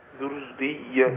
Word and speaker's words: ذرية: ذرية ذرية ذرية، يبقى الراء عندي والياء مشددتان.